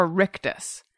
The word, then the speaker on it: rictus: Spell that. – R-I-C-T-U-S. – Rictus. – I think that's right. – I believe it's rictus, and it's like a like giant Joker-like smile that's plastered on your face that I have in all my photos.